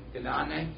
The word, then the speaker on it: Guinane: Ganane, okay, G-U-I-N-A-N-E.